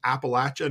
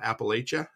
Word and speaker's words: Appalachia: He's from Appalachia, not Appalachia.